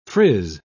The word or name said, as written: frizz